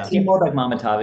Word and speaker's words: Timur Bekmambetov: I'm now going to mispronounce Timur Bekmambetov. Is that close? – Yeah, Timur Bekmambetov, exactly.